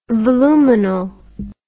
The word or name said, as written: voluminal